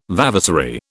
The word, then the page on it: vavasory: British English